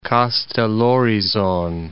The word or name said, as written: Kastellorizon